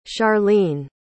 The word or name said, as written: charlene